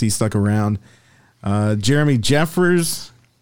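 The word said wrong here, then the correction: Jeffress: Jeffries. – Yeah, I guess that's how you pronounce it. There's only one E, though.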